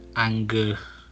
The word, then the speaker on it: Angarano: Mike Angarano? – That's the one.